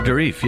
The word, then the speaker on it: Dourif: Brad Dourif. Is that how you pronounce? – Brad Dourif, yeah. That's what we were told.